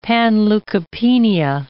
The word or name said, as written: panleukopenia